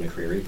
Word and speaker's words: McCreery: Again, David McCreary, pronounced correctly.